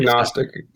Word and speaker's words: Gnostic: The G is silent, right? – I think Gnostic. Gnostic sounds right to me.